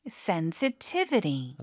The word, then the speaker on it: sensitivity: Sensitivity. – 아까 형용사형 발음. – Sensitive. – 명사형 발음. – Sensitivity. – 강세 바뀌죠?